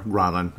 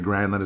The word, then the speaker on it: Granlund: Obviously, you've had guys like, you know, Mikhail Granlund, or Granlin, obviously that's the correct pronunciation, but Granlund is what we all got comfortable with, I suppose.